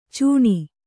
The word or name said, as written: ಚೀಣ